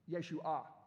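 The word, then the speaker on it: Yeshua (ישוע): The word there, the root word is the same as Yeshua. Excuse me, it's Yeshua. There you go. The emphasis is on the end of the word.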